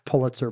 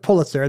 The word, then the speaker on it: Pulitzer: So, if you look it up in the dictionary, they will say it's pronounced Joseph Pulitzer, and they'll say that's the family's pronunciation. But then they will allow also Pulitzer, like if you want to say it the way the family didn't say it.